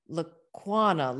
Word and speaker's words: Lacuona: She was always like Lacuona, like iguana.